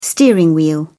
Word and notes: steering wheel: British English

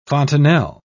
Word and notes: fontanel: British English

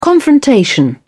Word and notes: confrontation: British English

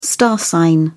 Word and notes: star sign: British English

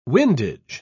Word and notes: windage: British English

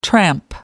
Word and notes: tramp: American English

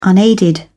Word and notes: unaided: British English

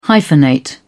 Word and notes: hyphenate: British English

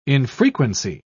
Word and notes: infrequency: British English

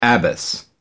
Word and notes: abbess: American English

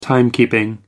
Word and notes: timekeeping: American English

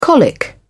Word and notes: colic: British English

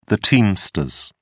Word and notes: teamsters: British English

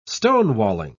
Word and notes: stonewalling: British English

